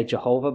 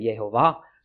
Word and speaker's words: Yehovah: I know we usually say Jehovah, but I guess you're supposed to pronounce it Yehovah.